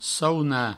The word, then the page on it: saunar: sauna